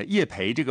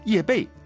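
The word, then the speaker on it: 叶蓓: 当然我们在这儿作为这个正规媒体呢，我们还是要特别的介绍一下叶培这个培啊，正确的读音是贝啊，草字头加一贝两贝的贝，就是贝蕾的贝啊，叶贝。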